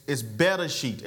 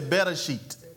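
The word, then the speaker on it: Bereshit (בראשית): But Genesis in the English is spelled G-E-N-E-S-I-S, but it has nothing to do with the original language. The original language, the original word, which is Hebrew, is Bereshit. Everybody say Bereshit. Everybody say Bereshit. One more time, say Bereshit.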